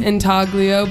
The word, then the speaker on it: intaglio: Intaglio, but the G is silent.